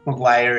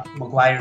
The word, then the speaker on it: Maguire: Maguire is spelled, if you remember that old film, Jerry Maguire. – Show me the money. – Maguire is spelled the same way, M-A-G-U-I-R-E.